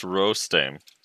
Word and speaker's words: Rostame: For the fourth time, it's pronounced Rostame.